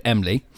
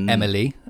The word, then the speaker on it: Emly: Moved from Ryslip to a little village called Emly. Not Emily, as you know. – As I know, yeah. I do know an Emily. – Without, it's spelt without the I, so Emly.